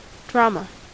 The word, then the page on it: drama: American English